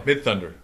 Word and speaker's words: Midthunder: Sorry, I said it wrong. It's Midthunder. – Oh, okay. oh Midthunder.